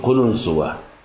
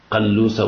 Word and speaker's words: قلنسوة: قلنسوة، طبعا هي تلفظ بألفاظ عديدة، قلوسة وقلنسوة وقلسات وقلسات وشغلات ألفاظ عند العرب لفظوها بألفاظ عديدة، إن قلنسوتي وقعت في بول فأخذتها فوضعتها على رأسي ثم صليت، فقال لا بأس.